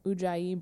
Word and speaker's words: ujjayi: she's using that yogi ujjayi breath. I said that wrong, I'm sure, but I think you said it right.